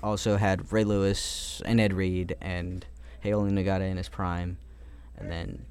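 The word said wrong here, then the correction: Haloti Ngata: Haloti Nata. – I don't know. Thank you. I didn't know how to say it. Haloti Nata.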